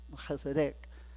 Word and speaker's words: Melchizedek: This Melchizedek is pronounced in Hebrew, Melchizedek.